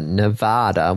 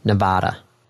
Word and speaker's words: Nevada: Nevada, with a long A in Vad, just like A in Ba. Like, right, ba, so like a sheep. Nevada.